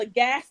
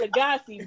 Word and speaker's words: Lagasse: Look at you, little Emeril Lagasse. You gotta say it wrong. It's Lagasse, but like you better fucking hype me up if I'm in here making shit like risotto, because I'm a regular nigga.